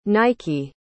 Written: nike

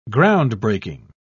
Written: groundbreaking

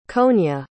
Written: konya

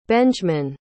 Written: benjman

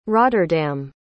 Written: Rotterdam

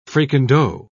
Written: fricandeau

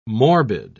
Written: morbid

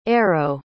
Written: aero

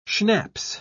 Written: schnapps

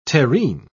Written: terrine

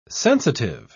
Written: sensitive

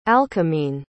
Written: alcmene

